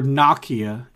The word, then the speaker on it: Nokia: What did Nokia, or Nokia, if you want to say it correctly, what did Nokia originally sell? – Dishwashers.